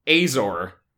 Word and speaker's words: Azor: And it's pronounced Azor. Specifically with the A being like, I really appreciated how she told this to me. You know how the character of the Fonz on Happy Days goes, hey!